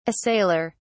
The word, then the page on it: assailer: British English